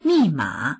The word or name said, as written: mi4ma3